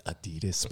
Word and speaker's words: Adidas: It's Adidas, people.